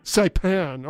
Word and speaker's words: Saipan: Saipan.